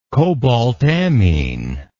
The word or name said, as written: cobaltammine